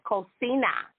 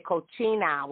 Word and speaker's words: cocina: But the word for kitchen is cocina. And because I was so used to the C and the I making the ch sound in English, I mispronounced when I was an adult volunteering at a multicultural school. And I said cochina, which is an offensive word.